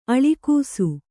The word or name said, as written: ಅಳಕಿಸು